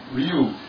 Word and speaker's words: Wriu: That was Ryu. W-R-I-U. – Yeah, that's your typical silent word. Silent W.